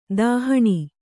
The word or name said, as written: ದಹನ